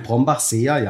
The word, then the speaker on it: Brombachseer: Ja, Prost, ein Brombachseer mit S-E-E-R hinten, spricht man das, ein Seher? – Ein Brombachseer, ja.